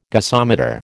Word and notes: gasometer: American English

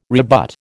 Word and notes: rebut: American English

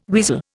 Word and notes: weasel: Indian

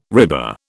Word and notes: RIBA: British English

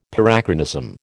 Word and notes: Parachronism: American English

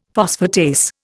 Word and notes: phosphatase: Indian

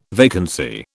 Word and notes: vacancy: British English

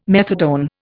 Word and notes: methadone: Indian

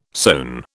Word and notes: sone: British English

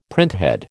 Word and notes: printhead: American English

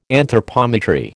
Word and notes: anthropometry: American English

